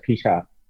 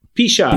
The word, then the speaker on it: Picha: Picha uh, Yes, Picha. Picha.